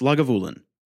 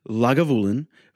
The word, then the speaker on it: Lagavulin: So he says, just to note, the whiskey you mentioned on the last show is pronounced Lagavulin. Yes. No, it's not. I'm sorry. It's not. It's pronounced Lagavulin. I have been drinking Lagavulin for many years.